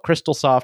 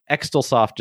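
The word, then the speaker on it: Xtalsoft: And this was developed by the company Crystalsoft, but it's spelled X-T-A-L. So we're just going to call it Crystalsoft because Extalsoft just doesn't sound right.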